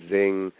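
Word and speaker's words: Xing: But there are lots of similar sites to LinkedIn out there, like Zing, which is spelt with an X, and Viadio.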